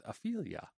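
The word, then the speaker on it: Afeela: I'm going to go with Aphelia. Aphelia. – Like Ophelia? Like the name? – The old Aphelia. Aphelia. – Avila? Alex Avila? – It's A-F-E-E-L-A.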